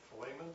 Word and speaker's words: Philemon: There's a Philemon, is that how you pronounce that name? – Yes.